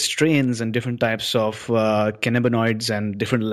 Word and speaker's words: cannabinoids: So three key things which I noted from what you said was there are over 100 different types of cannabinoids.